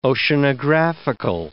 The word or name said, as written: oceanographical